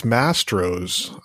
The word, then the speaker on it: Mastro's: Maestro's or Maestro's to be specific. – I believe it's Mastro's.